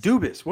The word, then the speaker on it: Dubas: Dubas.